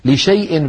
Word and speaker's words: لشيء: ولا تقولن لشيء إني فاعل ذلك غدا، في سورة الكهف، لشيء بين الشين والياء هناك ألف مكتوبة تكتب ولا تنطق، لا يصح حذفها من الخط أبدا، ولا يصح بالمقابل قراءتها.